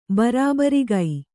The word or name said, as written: ಬರಾಬರಿಗೈ